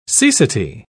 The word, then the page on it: cecity: British English